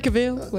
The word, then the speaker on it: Cavill: Henry Cavill, whatever.